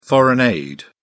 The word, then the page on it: foreign aid: British English